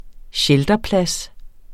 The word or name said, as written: shelterplads